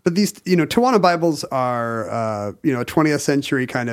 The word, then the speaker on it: Tijuana: Tijuana is the correct pronunciation.